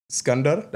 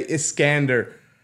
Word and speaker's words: Skandar: My name is Skandar. That's my Arabic name, my from back home. But here in Canada, not everybody knows how to say it. I actually get very, very frustrated when people say Iskander, you know?